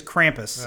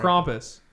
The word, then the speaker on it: Krampus: I think it's Krampus. – Yeah, it's pronounced it's Krampus. Krampus. It's German. Now, oh, that's Belschnickel.